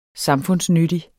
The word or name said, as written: samfundsnyttig